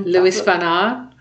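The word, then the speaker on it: Luis von Ahn: Oh God, I've lost the Lewis Van Aar.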